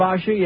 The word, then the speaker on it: Kvasha: Kvasha, am I saying that right? – Kvasha, yeah.